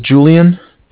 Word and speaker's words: Julian: All right, my name is Julian. It's J-U-L-I-A-N, and I'm calling from...